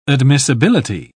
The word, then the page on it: admissibility: British English